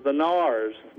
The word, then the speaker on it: Narrows: It's called or spelled the Narrows, N-A-R-R-O-W-S, but the local pronunciation is the Nars, more like N-A-R-S.